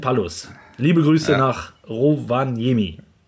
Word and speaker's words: Rovaniemi: Viele Grüße aus Rovaniemi. Ich hoffe, ich habe es richtig, ja, ausgesprochen. – Rovaniemi, ja.